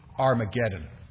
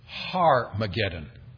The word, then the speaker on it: Armageddon: Then verse 16 says, and they gather them together to the place called in Hebrew Armageddon. I should explain, by the way, that the better pronunciation here is Har-mageddon. Har with an H.